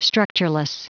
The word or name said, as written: structureless